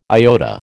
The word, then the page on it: iota: American English